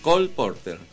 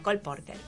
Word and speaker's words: Cole Porter: Cole Porter. ¿Cómo se dice? – Cole Porter. – Bueno, Cole Porter.